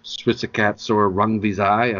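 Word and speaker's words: Srisaket Sor Rungvisai: And it doesn't matter that he lost by knockout in the rematch to Switzerkatsor Rungvisai. I don't know if I'm saying that correctly. – You're saying it exactly correctly.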